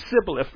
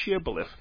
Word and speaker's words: shibboleth: They couldn't, their accent would not allow them to say shh. It would always come out sibboleth rather than shibboleth.